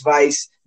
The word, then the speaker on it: Weiss: The Miami Vice, or it's spelled Weiss, but it's Vice, German.